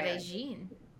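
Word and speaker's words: Medellín: I did not realize how built up Medellin, which is how they pronounce Medellin. It there. Medellin. Oh. No, they do Jean, like J-I-N. – Medellin. – Instead of Yin, it's Jean. I looked this up.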